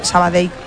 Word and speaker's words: Sabadell: A mí me lo decían mucho cuando estaba en Primera Federación, porque yo también decía Sabadell, que es como se dice.